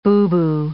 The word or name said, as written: boo-boo